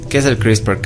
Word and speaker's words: CRISPR: se pronuncia CRISPR, CRISPR Cas9. Ya se escribe C R I S P R CRISPR. ¿Qué? CRISPR. CRISPR. – Sin la E, ¿no?